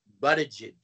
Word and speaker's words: Buttigieg: His last name is pronounced Buttigieg.